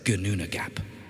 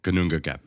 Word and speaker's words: Gnungagap: Before there was soil or sky or any green thing, there was only the gaping abyss of Gnungagap. I said that wrong. – Gnungagap.